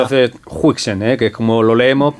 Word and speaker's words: Huijsen: Pues en el cómo pronunciar, que buscamos todos en neerlandés, dicen Huijsen, porque Huis sería el equivalente al house británico, es decir, casa, y el sen es el equivalente al son, al hijo.